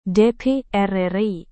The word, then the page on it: dpr ri: Indonesian